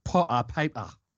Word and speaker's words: Potter Payper: Well, for the benefit of our American listeners, I'm going to pronounce his name in an exaggerated way. But a bloke called Potter Payper. – Potter paper.